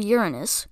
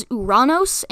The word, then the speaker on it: Uranus: He also discovered Uranus, whose original name was the Georgium Sidus. You can also pronounce Uranus as Uranos and, of course, the infamous Uranus.